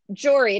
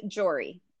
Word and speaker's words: Joree: Joree. It's like Lori with a J. – Like Murray with a J? – Lori. So jo- it's my name is Joree.